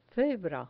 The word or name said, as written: cebra